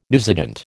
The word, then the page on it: newsagent: American English